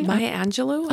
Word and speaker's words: Maya Angelou: Maya Angelou.